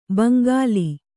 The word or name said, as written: ಭೋಂಕಲ್